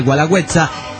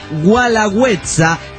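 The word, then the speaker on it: Guelaguetza: Del cerro en Oaxaca, el Gualagüenza. sino Gualagüetza, como se dijo en el programa pasado.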